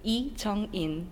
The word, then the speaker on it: Lee Jung In: But correctly, I want to say my name as a Korean version, Lee Jung In.